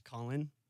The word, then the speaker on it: Caulin: You can find me on Instagram and TikTok at Trash Caulin. It's trash, and my name is spelled C-A-U-L-I-N.